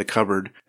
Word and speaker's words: cupboard: They're pronouncing the P. Instead of it being silent. I mean, you don't really pronounce the P in that word. It's cupboard.